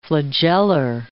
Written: flagellar

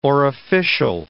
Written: orificial

